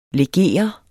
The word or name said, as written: LEGEREDE